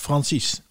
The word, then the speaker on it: Francis: Denk PS, Francis spreek je uit op zijn Nederlands en niet op zijn Engels. – Francis. Is het dan Francis of Francis? – Francis.